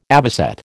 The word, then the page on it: avocet: American English